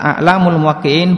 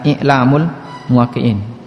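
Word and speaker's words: I'lamul Muwaqqi'in (إعلام الموقعين): Kalau dibaca A'lamul Muwaqqi'in berarti daftar ulama, daftar alim yang memberikan fatwa. Kalau dibaca I'lamul Muwaqqi'in, I'lam berarti rambu-rambu ma'alim dalam memberikan fatwa. Dan yang lebih tepat cara membacanya adalah I'lamul Muwaqqi'in.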